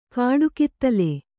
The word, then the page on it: ಕಾಡುಕಿತ್ತಲೆ: kāḍu kittale